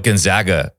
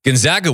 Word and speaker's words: Gonzaga: Gonzaga was the Cinderella. As a small school out of Spokane that people couldn't even pronounce right, Gonzaga. People still say Gonzaga. It drives me nuts.